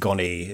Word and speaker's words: Gonet: Is it Stella Gonet? Am I saying it right? – I would say so. Yeah, I think Gonet is the correct pronunciation.